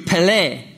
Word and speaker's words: pele: If you look up this word, the word wonderful is pronounced in the Hebrew, pele.